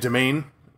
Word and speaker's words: demesne: And I've heard many pronunciations, so did Nate. And domain was the one that Nate offered, and that's how it sounds. I've heard people say that, no, it is domain.